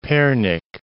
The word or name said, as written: Pernik